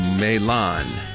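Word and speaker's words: Mahlon: 메일란. 한 번 더요. 메일란. 말론을 메일란 이렇게 발음하시고 기련은 킬리안, 킬리안, 메일란, 킬리안 이렇게 연습해 주시기 바랍니다.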